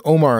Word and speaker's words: Aumar: Omar, I can't say it because they said Omar in the movie, but it's spelled because it's A-U-M-A-R. – Yeah, A-U-M-A-R.